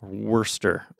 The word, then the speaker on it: Worcester: Like I figured out, because I lived in Boston for a while, and you know, I figured out the beginning part of that before shire that people get confused about. pronounced Worcester.